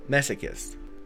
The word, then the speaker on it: masochists: In fact, they will seek out masochists.